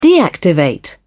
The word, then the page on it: deactivate: British English